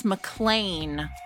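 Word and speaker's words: MacLean: I feel like even your name, people never know to say McLean or McLean. So yes, it's McLean.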